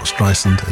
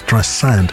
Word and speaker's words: Streisand: I'm told it's pronounced not Streisand, as Streisand.